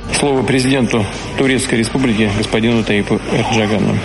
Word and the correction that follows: Ердоган: Реджепу Таїпу Ердогану.